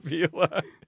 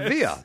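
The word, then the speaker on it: Vila: I like that you pronounce the double L as though Bob Villa is from Buenos Aires. – It's Bob Villa. Bob Villa.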